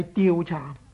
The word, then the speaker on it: 调查: 在这处呢，我希望大家留意一下这个词语的正确读音，应该呢是调查。